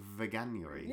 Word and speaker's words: Veganuary: Can I just ask, did you pronounce it veganary? – Yeah, did I? – Is that how you say it? – I think so. Sounds okay. I always, because nobody says it out loud. I only ever see it on Instagram. I thought it was called veganuary.